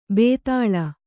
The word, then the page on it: ಭೂತಾಳ: kannada; bhūtāḷa